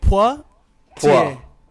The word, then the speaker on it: Poitiers: It's like P-W-A, like Poitiers.